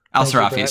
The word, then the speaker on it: al-Sirafi: Al-Sirafi, sorry.